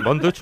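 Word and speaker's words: Von Dutch: Así que, Bon Dutch, ¿lo he pronunciado bien? – Sí, muy bien.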